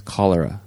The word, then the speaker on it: cholera: On a previous episode, I was saying cholera, but the correct pronunciation is cholera.